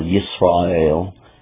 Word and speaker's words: Yisrael: If you are Israel in the time, or Yisrael, is how it's pronounced in Hebrew,